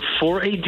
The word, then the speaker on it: 4AD: Zeg, de eerste show die is op zaterdag 31 oktober in Club 4AD of 4AD. Ik weet niet hoe ik het moet uitspreken. Waarschijnlijk 4AD. – Ja, de meeste mensen zeggen 4AD.